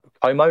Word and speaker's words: Como: So when you're out on loan, so you've been Torquay, Dartford, and Como.